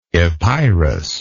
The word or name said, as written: epeirot